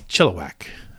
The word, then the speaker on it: Chilliwack: Like, is it is it Chilliwack? Is it Chilliwack? Is it Chilliwack? And Chillack. she's like, it's Chilliwack. Like, don't like, overthink okay.